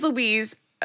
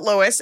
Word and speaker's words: Lois: Her name is Louise. They spell it Lois, and I have to remind myself, it is Louise.